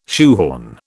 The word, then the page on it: shoehorn: British English